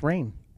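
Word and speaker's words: Rahne: It's pronounced Rain.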